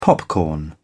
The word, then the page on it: popcorn: British English